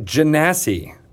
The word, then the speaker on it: Genasi: You pronounced it correctly. – I did. Okay. Yes. Phew. – What? – Wait, so it's derived from genie? – Yep. – Is that correct? – That's correct. – Genasi, not Genasi.